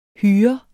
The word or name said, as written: hyr